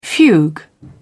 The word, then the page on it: fugue: British English